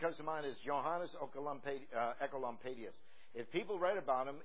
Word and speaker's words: Oecolampadius: His name is Johannes Ecolampadius, and it's spelled O-E-C-O-L-A-M-P-A-D-I-U-S, but you pronounce it O-E as Echo.